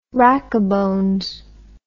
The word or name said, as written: rackabones